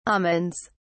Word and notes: almonds: British English

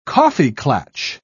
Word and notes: kaffeeklatsch: British English

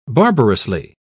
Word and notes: barbarously: British English

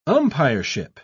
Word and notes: umpireship: British English

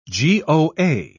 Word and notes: goa: British English

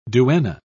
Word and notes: duenna: British English

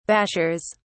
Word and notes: bashers: British English

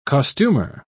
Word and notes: costumer: British English

